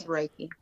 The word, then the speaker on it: Reiki: So we wanted to get on here to talk about spiritual healing, quantum healing, and is it Reiki? Reiki healing? Am I pronouncing that right? Reiki? – It's Reiki.